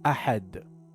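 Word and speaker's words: ahad (أحد): Correction: ensure proper articulation of ahad with a correct guttural sound of ha.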